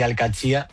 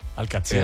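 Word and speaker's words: Al-Qadsiah: Bueno, se suele decir Al-Qadsiya. – Al-Qadsiya. – Pero también hay gente que lo dice Al-Qadsiya.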